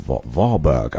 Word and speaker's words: Warburg: a gentleman by the name of Dr. Otto Warburg.